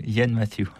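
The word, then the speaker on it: Iain Matthew: je ne sais pas comment on prononce son nom, Yen Matthieu. – Oui, Yen Matthieu.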